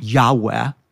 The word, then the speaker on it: Yahweh: Yahweh. Most Semitic scholars believe it's pronounced that way.